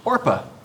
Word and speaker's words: Orpah: Oprah's birth name is actually Orpah, taken from this very passage of scripture. But in her life, everybody kept mispronouncing it. They kept calling her Oprah, and it just kind of stuck. But really, her name is Orpah Winfrey.